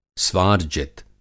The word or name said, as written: स्वार्जित